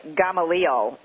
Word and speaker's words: Gamaliel: Yes, it's G-A-M-A-L-I-E-L Foundation. You're tempted to say Gamaliel, but I believe I've pronounced it properly. Gamaliel is how you pronounce it.